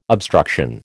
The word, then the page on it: obstruction: American English